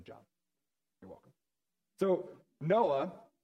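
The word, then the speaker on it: Noah (נח): it's Noah. It's got a little like phlegmy sound at the end, so in the Bible, if you're reading in Hebrew, it's Noah, which is just more fun.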